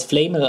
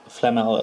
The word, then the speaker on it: Flamel: Halt in der Rufus Beck Hörbuchversion hat das Flamel ausgesprochen. Das ist die falsche Aussprache. Flamel. Flamel ist, glaube ich, richtig.